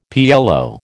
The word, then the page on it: PLO: American English